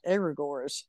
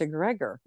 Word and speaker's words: egregore: And in my, in my tradition, I've always heard it pronounced that way, egregore. And then somebody else yesterday was like, "Oh no, it's a Gregor." And I was like, "Oh, okay."